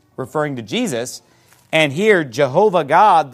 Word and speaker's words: Jehovah: Now, that's Jehovah that... L-O-R-D, that what's known as the Tetragrammaton, the four-letter Old Testament name of God that we pronounce in English as Jehovah.